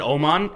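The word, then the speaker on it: Åman: They pronounce it Oman, and Oman. I think that's how you would pronounce it in, like, Swedish or whatever.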